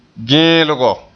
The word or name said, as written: geelgo